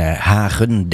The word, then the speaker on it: Hagen: En Hagen denk ik dat het gewoon dan uitgesproken moet worden als we dat dan in het Nederlands moeten doen.